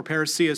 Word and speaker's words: parousia: He'll actually use two words in chapter 4, parousia in the Greek, or parousia, some people like to pronounce it.